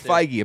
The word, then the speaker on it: Feige: is that how you pronounce his name? – Yeah, I thought it was Feige. – Feige, that's what I thought. – It's Feige, apparently.